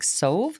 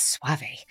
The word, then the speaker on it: Soave: It's a fun last name because it's spelled S-O-A-V-E. So you're like Sove, Suave, but it's Suave.